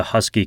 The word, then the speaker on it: Huskee: Ian also mentioned the Husky coffee cup, which I looked up and it's pretty cool. Husky is spelled H-U-S-K-E-E.